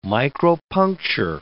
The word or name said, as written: micropuncture